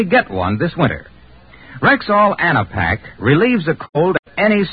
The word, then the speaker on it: Anapac: So, friends, before another cold starts coming on, be sure to have your Rexall Anapac on hand. That's spelled A-N-A-P-A-C.